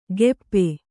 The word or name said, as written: ಗೆಪ್ಪೆ